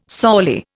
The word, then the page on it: sorely: Indian